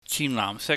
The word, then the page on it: chihnlaahmsik: Cantonese